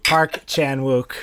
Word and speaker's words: Park Chan-wook: Yeah, the Park Wan-chuk. I can't say his name. Park Wan-chuk. – Park Chan-wook.